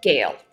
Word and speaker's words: Gale: Gale, just like the woman's name.